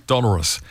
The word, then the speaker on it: dolorous: Well, I just checked the pronunciation before, actually, because I wasn't quite sure. And sure enough, it's dolorous.